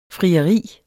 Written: FRIERIET

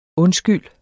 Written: undskyld

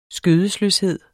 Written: skødesløshed

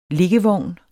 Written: liggevogn

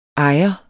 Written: ejer

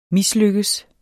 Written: mislykkes

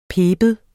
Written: piber

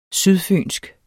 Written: sydfynsk

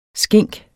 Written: skink

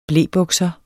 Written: blebukser

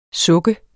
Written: suk

